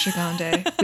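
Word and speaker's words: Gigandet: Gigande. – Yes, Gigande.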